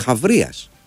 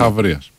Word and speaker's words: Χαβρίας: Τσάμπριας. Χάμπριας. Δεν ξέρω πώς ακριβώς διαβάζεται αυτό. Και τι είναι ακριβώς. Είναι. Α, συγγνώμη, Χαβρίας. Αθηναίος στρατηγός του 4ου αιώνα. – Χαβρίας.